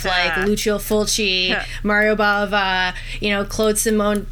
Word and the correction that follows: Simonetti: Simonetti, yes, and we met him at Horrorama.